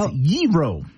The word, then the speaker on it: gyro: There's no G sound in there, apparently. It's gyro. – Gyro.